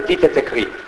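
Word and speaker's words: Dits et Écrits: Allora, si legge Dites et Écrits. Si scrive Dites et Écrits, con tutti gli accenti a posto,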